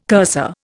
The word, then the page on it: cursor: Indian